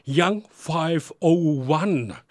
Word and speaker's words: Young 501: Spricht man das tatsächlich Englisch aus oder sagst du Young 501? – Nee, also erstmal hallo und ich sag mal herzlich willkommen zur Sendung. Spricht man tatsächlich so aus? Also ist richtig. Bin ich auch immer sehr froh, wenn Leute es richtig aussprechen.